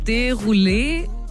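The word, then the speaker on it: Desrouleaux: Und sein Nachname wird, ich glaube, Derulé ausgesprochen. Derulé? Also geschrieben wird Desrouleux.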